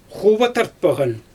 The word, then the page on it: xuba tartpaɣan: Xyzyl